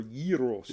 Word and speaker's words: gyros: What is the correct way to pronounce gyros? Gyros or gyros? – It's gyros.